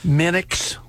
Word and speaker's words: Muenks: Jefferson City Academic Center, Terry Monks, and I apologize if I am mispronouncing that last name, Monks, M-U-E-N-K-S. – Minix, a lot of times you hear.